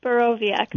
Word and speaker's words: Borowiak: So, say it for me one time. I'm just going to start practicing it. Borowiak? – Borowiak. – Borowiak. It's a V.